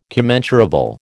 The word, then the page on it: commensurable: American English